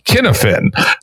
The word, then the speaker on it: Cynefin: He's probably best known as the inventor of the Cynefin framework, spelled C-Y-N-E-F-I-N. Till I did my research for this podcast, I always pronounced it something like Cynefin. Dave, how do we actually say that? – It's Cynefin. It's Welsh. It's phonetic, but we have a different alphabet.